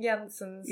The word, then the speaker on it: Janssens: How do I say her name? I think Janssens is correct.